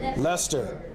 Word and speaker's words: Leicester: I said it wrong. Who said it right? – Leicester.